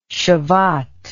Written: shevat

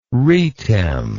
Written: retem

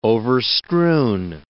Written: overstrewn

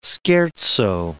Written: scherzo

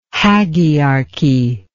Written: hagiarchy

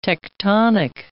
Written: tectonic

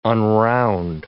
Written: unround

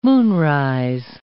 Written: moonrise

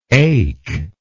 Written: ake

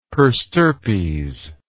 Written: per stirpes

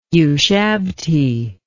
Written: ushabti

